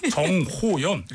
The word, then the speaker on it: 정호연: 자, 호프의 감독 나홍진, 배우 정원연. 발음 잘 안 됐어요. 정호연 이렇게 두 분과 함께하도록 하겠습니다.